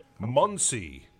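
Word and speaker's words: Monsey: Muncie. Muncie spelled like Monsie.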